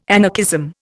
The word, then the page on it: anarchism: Indian